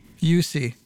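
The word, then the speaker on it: Juuse: I want to say Juicy Saros. – Juicy Smollett? – How do you say Saros' first name? – UC.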